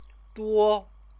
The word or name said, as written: duo